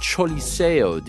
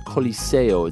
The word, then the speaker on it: Choliseo: Not the El Coliseo. It's the Choliseo. – Yeah, the Choliseo. – Even though it's pronounced C-O, it's actually, or it's spelled C-O, it's actually pronounced C-H-O in a roundabout way.